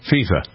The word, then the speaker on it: FIFA: I pronounce it right? – Yes. – FIFA.